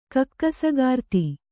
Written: ಕಕ್ಕಸಗಾರ್ತಿ

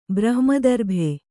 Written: ಬ್ರಹ್ಮದರ್ಭೆ

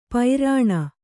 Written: ಪೈರಾಣ

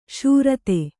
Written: ಶರಧಿ

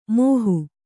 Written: ಮೋಹು

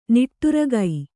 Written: ನಿಟ್ಟುರಗೈ

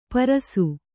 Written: ಪರುಸ

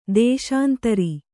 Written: ದೇಶಾಂತರಿ

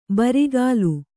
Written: ಬುರುಗಲು

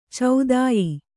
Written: ಚೋದ್ಯ

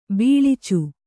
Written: ಬಿಳಿಚ

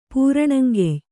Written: ಪೂರಣಂಗೆಯ್

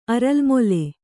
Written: ಅರಲ್ಮೊಲೆ

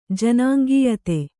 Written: ಜನಾಂಗೀಯತೆ

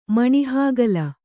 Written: ಮಣಿಹಾಗಲ

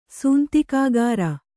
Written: ಸೂಂತಿಕಾಗಾರ

